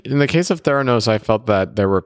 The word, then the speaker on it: Theranos: like Theranos. Did I pronounce that correctly? – Yes.